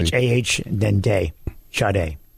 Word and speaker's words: Sade: Now, the internet does say there was an album or something printed called by Epic Records called Pronounced Shar Day.